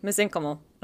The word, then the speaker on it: mesenchymal: And so I was reading out loud a title to my dad, and I was like, and mesenchymal cell, and he was like, mesenchymal.